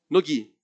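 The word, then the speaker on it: No-Gi: Que é o sem kimono, no Campeonato Mundial de Nogi, é assim que pronuncia mesmo? Nogi. É, Campeonato Mundial Nogi,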